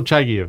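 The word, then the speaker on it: Chagaev: Okay, I always mispronounce his name. Chagiev? – Chagiev? Russell Chagiev? – Russell Chagiev. That's what it is.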